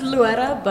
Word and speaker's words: Loera: You can say like Loera, like with a D, Loera, Loera. – Really? – Yeah. – How's a D get in there? – Well, because you're supposed to roll your R, but if you can't roll your R, then it's like Loera.